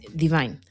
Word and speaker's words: Dvyn: Ella es Divine. Lo he dicho así, pero se escribe D-V-Y-N.